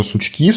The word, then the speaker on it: Сучки: И после этого Роспатент предоставил правовую охрану товарному знаку «Сучки» с указанием, что вот здесь ударение на последний слог.